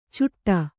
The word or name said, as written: ಚುಟ್ಟ